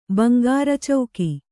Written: ಬಂಗಾರಚೌಕಿ

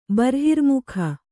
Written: ಬರ್ಹಿರ್ಮುಖ

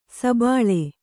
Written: ಸಬ್ಬಲು

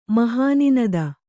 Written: ಮಹೋನ್ನತಿ

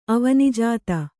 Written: ಅವನೀಜಾತ

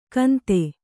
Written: ಕೌಂದ

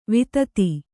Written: ವಿದಿತೆ

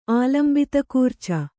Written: ಆಲಂಬಿತಕೂರ್ಚ

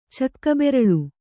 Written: ಚಕ್ಕಬೆರಳು

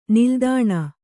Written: ನಿಲ್ದಾಣ

